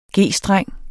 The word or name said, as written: G-streng